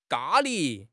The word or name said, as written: ga1 li2